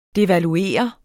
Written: devaluere

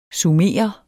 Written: sumerer

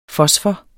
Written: fosfor

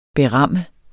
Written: beram

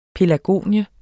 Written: pelargonie

